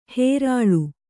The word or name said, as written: ಹೇರಾಳು